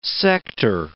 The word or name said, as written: sector